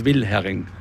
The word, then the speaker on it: Wilhering: Also meistens wird das H verschluckt. Ja. Wilhering. Auch ich ertappe mich immer dabei, aber die korrekte Aussprache ist Wilhering. Mit H.